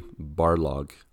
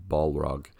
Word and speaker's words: Barlog: And the same with Corey Barlog. I want to make sure to say that right because I always want to say Balrog.